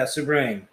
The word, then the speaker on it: Souverain: So, yeah, it is Chateau Sauvignon. – Sauvignon. Yeah. That's it. Oh, wait. Souverain. – Yeah, Souverain. – Chateau Souverain. We'll go with that.